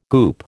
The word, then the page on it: goop: American English